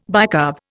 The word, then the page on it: bicarb: Indian